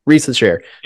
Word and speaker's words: Risacher: But the other thing with uh, Reese's, how do you, I, have always I've heard multiple pronunciations. – Risacher. – Yep.